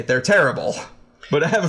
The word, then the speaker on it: Terrible: Well, being that Unibrow, they're located in Quebec, so it would be the Terrible. – The Terrible. – Yes. – Okay, but spelled terrible.